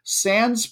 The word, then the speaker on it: Sandys: Sands Parish, which is spelled S-A-N-D-Y-S, but it is not possessive, nor do you pronounce the Y, it turns out, which I just learned this week, even though I've talked about it before.